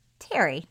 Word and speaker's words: Thierry: And by the way you pronounce it, it's slightly confusing because it's spelled T-H-I-E-R-R-Y. – Well, he's French. – So it seems like it would be Thierry. But anyway, it's Terry.